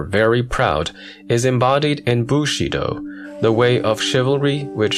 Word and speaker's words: Bushido: The modern Chinese pronunciation is Wu Shi Dao. In Cantonese, Mushido, very similar to tongue pronunciation, whence the Japanese Bushido.